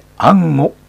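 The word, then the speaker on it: 安居: 安心の安に居住の居と書いて安吾と読みます。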